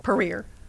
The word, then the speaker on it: Puryear: Puryear. – I knew that. – The Y is silent.